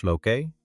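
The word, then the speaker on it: lykke: Likken, uitgesproken als loké, betekent in het Deens geluk.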